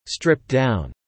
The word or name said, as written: stripped-down